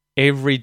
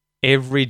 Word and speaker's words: every: So, I wonder if you noticed with the word every, when I say this naturally, I actually only use two syllables when pronouncing this word, right? Every. Whereas it looks like there are three. Every. And you can use three if you really want.